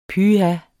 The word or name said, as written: pyh